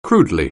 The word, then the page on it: crudely: anglais